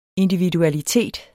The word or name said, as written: individualitet